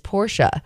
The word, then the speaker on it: Porsche: Porsche is often pronounced two different ways, but the real pronunciation is Porsche, which explains why my dad calls it a Porsche.